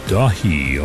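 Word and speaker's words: Daithi: A new album from Ilion called Dahi, although it's spelled D-A-I-T-H-I. I'm pretty sure it's in Gaelic.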